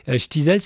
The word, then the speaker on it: Shtisel: Ch'tizol, c'est S-H-T-I-S-E-L, les Ch'tizol.